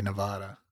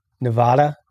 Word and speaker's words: Nevada: I always say Nevada. – Nevada with a soft A instead of the Nevada. That's like a Chicago way of saying it, I think. The hard A, Nevada.